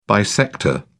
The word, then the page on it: bisector: British English